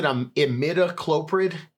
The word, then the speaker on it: imidacloprid: It's probably just a light imichloroprid collar, just hanging out. You just get to breathe a little bit of it, like, constantly. – How do you say it? Is it imidacloprid? Is that right?